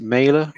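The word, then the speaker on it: Mæhle: Then they got sucker punched with it was a goal from Dolberg, but an unbelievable cross from, is it Mailer? – Yeah. – How do you pronounce it? – Yeah, Mailer? – That's it, yeah.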